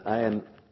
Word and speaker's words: ayin: And the ayin in modern Hebrew is a silent language, a silent letter. But in its origin, it was a guttural letter. So now in Hebrew you don't pronounce the ayin, but really the sound of the ayin was come from deep in the throat.